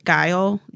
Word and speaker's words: guile: This debt we pay to human guile, guile, guile. Which one? G U I L. – Guile. Guile. – Said it right the first time. Disregard anything else.